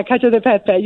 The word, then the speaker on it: Cacio e Pepe: And I got to tell you, my favorite at Pizzeria Portofino, and I don't know that I say it right, Cacio e Pepe. – Ah, yeah. Cacio e Pepe.